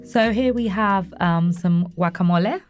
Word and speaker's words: guacamole: Okay, see, everybody else, like me, guacamole, you call it, you don't pronounce the G.